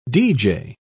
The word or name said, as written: deejay